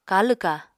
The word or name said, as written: kalw-kaha